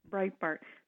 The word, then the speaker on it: Breitbart: And it turns out, lo and behold, is it pronounced Breitbart? Is that the newspaper? – Breitbart.